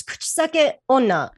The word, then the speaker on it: kuchisake onna: It's kuchisake onna.